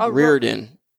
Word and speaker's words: O'Riordan: Dolores O'Riordan? O'Riordan. Is that how you say it? – Yeah.